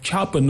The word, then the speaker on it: Japan: Even the word Japan, the stress is not on the first syllable, like Japan, no, it's Japan, second syllable.